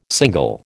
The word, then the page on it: single: American English